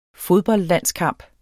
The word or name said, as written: fodboldlandskamp